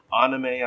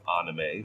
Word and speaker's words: anime: Now, anime, anime, anime, I believe, is the Japanese pronunciation, so that's very acceptable. You might, though, find that people who aren't familiar with anime,